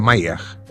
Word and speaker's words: Mayer: Em 1973, quando o conheci, o Parque Mayer, em Lisboa, escreve-se Meyer com Y, se pronuncia Mayer.